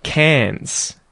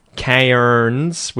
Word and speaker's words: Cairns: Anyway, guys, that aside, the intro scene there was about bats in Cairns. And notice there, I don't pronounce it Cairns with the R. We pronounce that as Cairns, the city in Queensland, right?